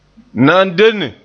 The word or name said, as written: nandenne